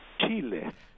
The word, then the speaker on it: Chile: Chile.